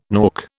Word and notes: nork: British English

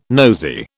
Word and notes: nosey: British English

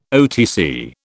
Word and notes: OTC: British English

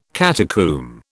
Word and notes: catacomb: British English

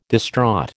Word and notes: distraught: American English

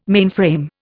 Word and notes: mainframe: Indian

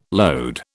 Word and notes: load: British English

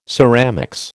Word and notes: ceramics: American English

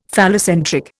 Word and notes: phallocentric: Indian

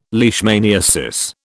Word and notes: leishmaniasis: British English

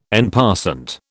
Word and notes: en passant: British English

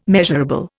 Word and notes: measurable: Indian

